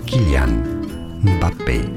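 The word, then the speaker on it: Mbappé: In French, in France, it said as Kylian Mbappe.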